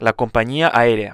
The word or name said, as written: la compania aerea